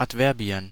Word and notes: Adverbien: /atˈvɛrbi̯ən/

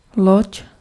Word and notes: loď: [lɔc]

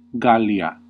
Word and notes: Gallia: Classical; /ˈɡal.li.a/

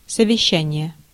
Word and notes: conseil: France; /kɔ̃.sɛj/

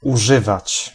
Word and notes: verwenden: /fɛɐ̯ˈvɛndn̩/; /fɛɐ̯ˈvɛndən/

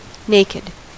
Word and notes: naked: American English; [ˈneɪ.kɪd]

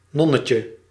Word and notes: nonnetje: /ˈnɔnətjə/

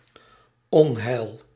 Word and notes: désastre: France; /de.zastʁ/

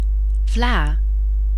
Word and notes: custard: /ˈkʌs.tə(ɹ)d/; [ˈkʌs.tə(ɹ)d]

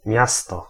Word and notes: miasto: /ˈmjastɔ/

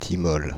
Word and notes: thymol: France (Île-de-France); /ti.mɔl/

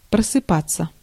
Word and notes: wake: /ˈweɪk/

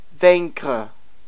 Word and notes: defeat: /dɪˈfiːt/; American English

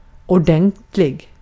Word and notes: thorough: /ˈθʌ.ɹə/; /ˈθʌ.ɹoʊ/; [ˈθʌɹ.oʊ]; [ˈθɝ.oʊ]; /ˈθʌɹəʊ/

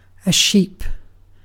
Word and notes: sheep: [ʃip]; /ˈʃiːp/

